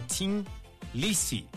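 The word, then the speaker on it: Thin Lizzy: La banda cambió la T por la TH para imitar un poquito el acento dublinés. Dice que suele pronunciar la TH como T, dejando el nombre como Tim Lizzy.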